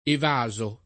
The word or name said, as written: evaso